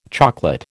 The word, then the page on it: chocolate: American English